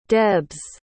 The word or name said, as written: debs